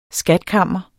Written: SKATS

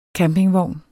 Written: campingvogn

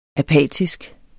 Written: apatisk

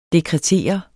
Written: dekretere

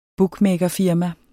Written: bookmakerfirma

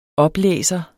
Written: oplæser

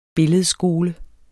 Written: billedskole

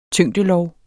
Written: tyngdelov

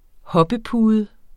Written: hoppepude

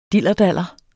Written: dillerdaller

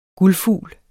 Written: GULDFUGLS

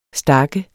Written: stak

